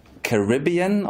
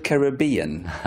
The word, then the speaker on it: Caribbean: Is it Caribbean or is it Caribbean? I hover now between both. I think the American pronunciation is Caribbean, but Caribbean I think is the Anglo emphasis, I believe. But the indigenous people of those islands are the Caribs. Caribbean is probably more correct.